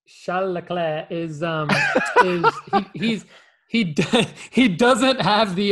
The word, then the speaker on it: Charles Leclerc: And as good as Charles Leclerc is, I probably butchered that in the French.